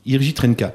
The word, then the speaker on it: Jiří Trnka: donc c'est Jiří Trnka. – Jiří Trnka.